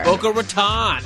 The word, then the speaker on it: Boca Raton: Boca Raton.